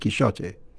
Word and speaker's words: Quixote: And even in Spanish, in the Spanish of the time of Cervantes, the X would have been pronounced as a sh, not as a her. You know, so Cervantes himself would probably have said Quixote.